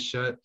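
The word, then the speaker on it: Schutt: And for those of you that don't know, shut spelled S-C-H-U-T-T. I think that's how you pronounce it too. Yes. I think it is shut.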